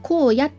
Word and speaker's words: 荒野: 作家の井上光晴の娘である井上荒野、これ、荒野って書いて荒野って読むんですけど、井上荒野が、父光晴と母、そして瀬戸内寂聴をモデルに、男女 3 人の特別な関係を綴った小説。